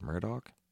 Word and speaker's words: Murdaugh: And his last name is spelled Murdaug, and it's pronounced Murdaugh.